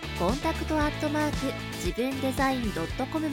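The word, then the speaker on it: contact@jibundesign.com: 読み方はコンタクトアットマーク自分デザイン .com までお待ちしています。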